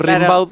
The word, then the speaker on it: Rimbaud: Claro, como se escribe, se pronuncia Gamboa, pero es Rimbaud.